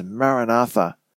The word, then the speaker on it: Maranatha: Now, come Lord Jesus is the Aramaic expression that was well known in the ancient church, and that is pronounced as Maranatha or Maranatha.